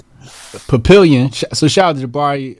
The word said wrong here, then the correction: Papillon: Yeah, this is or is it Papillon? How do you pronounce that? Papillon. – Papillon. – Papillon. I'm sorry, Papillon 25 in South Orange, New Jersey.